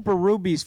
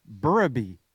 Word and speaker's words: Berube: He goes, it's not Berube. Everybody in the NHL calls it Berube, but it's actually Burraby. I go, seriously, it's Burraby.